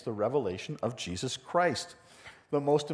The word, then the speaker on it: Revelation: So Revelation, here's a really quick overview of the book of Revelation. First of all, it is pronounced Revelation, singular, not Revelations.